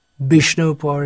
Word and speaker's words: Vishnupur: There are so many places, Vishnupur in Bengal, Vishnupur it's pronounced.